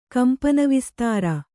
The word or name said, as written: ಕಂಪನವಿಸ್ತಾರ